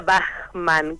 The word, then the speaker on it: Bachmann: Y en realidad se pronuncia Bachmann, como el compositor,